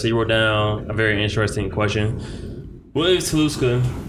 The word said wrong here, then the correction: Tulsa: Tulsa? – I was gonna say Tulsa.